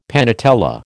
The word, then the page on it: panatella: American English